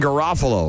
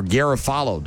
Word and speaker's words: Garofalo: Speaking of people that are in the movie Romeo and Michelle, Janine Garofalo, or Garofalo, depending on how you prefer to pronounce it.